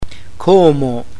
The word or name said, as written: como